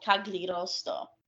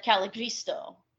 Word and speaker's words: Cagliostro: So the steel is actually called Cagliostro. – Mm-hmm. – And I probably mispronounced that again. But I said Caligristo, and I said 42 inches of Caligristo steel, tickling his heart, from the outside.